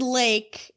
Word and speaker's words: Laich: It's the word lake, L-A-I-C-H. – Can I just say the term lake, like L-A-K-E, and it be okay?